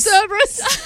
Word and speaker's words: Cerberus: It's pronounced Cerberus.